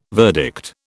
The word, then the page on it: verdict: British English